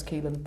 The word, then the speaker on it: Caoilfhionn: So it's a classic Irish name with multiple letters that you don't pronounce. So C-A-O-I-L-F-H-I-O-N-N. Just pronounce Keelan, but that's how you spell it.